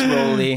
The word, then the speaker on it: Rowley: It's Rowley.